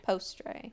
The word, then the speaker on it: postre: Postre. – I'm pretty sure you gotta pronounce like pastry? – O's like O.